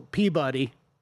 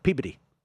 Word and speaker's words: Peabody: How do you say it? – Man, well, Peabody. – No, Peabody.